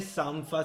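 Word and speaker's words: Sampha: Allora, Jesse Ware si scrive Jessie con la J W-A-R-E e Sanfa si scrive S-A-M-P-H.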